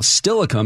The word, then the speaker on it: Steilacoom: Now, Steilacoom has been the accepted pronunciation for decades, according to Hugh Rundle's Guide to Place Names, published by Wazoo back in the 60s.